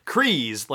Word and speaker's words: Kryze: So Kryze, like freeze, is how you pronounce Bo Katan's last name.